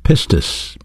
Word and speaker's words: pistis: You know, faith is a noun, and in the Greek language it's pronounced pistis, P-I-S-T-I-S. It has a verb counterpart, and that's the word believe. Pistuo, P-I-S-T-E-U-O.